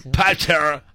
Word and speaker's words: Panettiere: Panettiere. ¿Sí se dice así? – Panettiere.